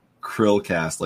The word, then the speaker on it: Chrillcast: Chrillcast, like the little shrimp Krill, thing. Krill, okay.